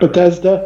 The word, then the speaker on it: Bethesda: So if, if Shinji can pull this off and Bethesda, uh, uh, uh, Bethesda, am I, pre- I don't know I'm pronouncing that. – Bethesda. Right. Bethesda. I can Bethesda. never pronounce that.